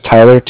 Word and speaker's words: Tyler: Like I said, my name's Tyler, T-Y-L-E-R, and I'm calling with Cardiff. It's spelled C-A-R-D-I-F-F, as in Frank.